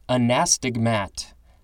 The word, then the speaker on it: anastigmat: It says it's a Navajo word, Anasazi, A-N-A-A-S-A-Z-I, and it literally means enemy ancestors. Now we have anastigmat. I think that's the correct emphasis on the syllables and pronunciation. A-N-A-S-T-I-G-M-A-T.